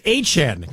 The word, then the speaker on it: Etienne: Yeah, new way to say Etienne. It's Achan, kind of like Achan in Miami, but it's spelled Ettienne, Achan. All right.